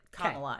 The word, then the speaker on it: Kamala: Just think Mamala, replace it, Kamala.